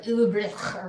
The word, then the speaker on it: Ulbrich: If I said it properly, it'd probably be like Ulbrich or something like that.